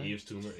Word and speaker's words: Yves Tumor: Yves Tumor, yeah.